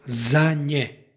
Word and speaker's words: za nje: stihu pročitali smo za nje, ovaj veznik kojim počinje ovaj stih izgovara se sa nj u drugom slogu, dakle za nje.